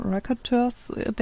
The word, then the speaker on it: Raconteurs: Raconteurs denken müssen.